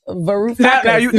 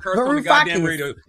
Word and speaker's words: Varoufakis: Giannis Farouk. – Yeah, exactly. – Varoufakis. – Now they on stage. Now they mad because you said their name wrong. – Varoufakis.